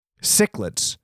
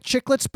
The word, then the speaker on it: cichlids: Cichlids. Looks like chicklets, but the word is cichlids. It's spelled chicklets, but it's said cichlids.